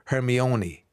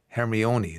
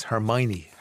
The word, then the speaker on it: Hermione: You see, it's the way it's spelled, isn't it? H-E-R-M-I-O-N-E. You would go Hermione, Hermione. It's Hermione, Hermione.